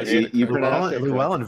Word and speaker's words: Llewellyn: So from a wonderful Parliament member, stop me if I mispronounce his last name, Troy Llewellyn. I believe. – Yeah, Llewellyn, very nice Welsh name.